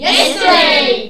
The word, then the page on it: yesterday: english